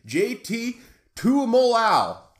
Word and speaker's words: Tuimoloau: JT Tuimoloau.